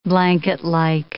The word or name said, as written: blanketlike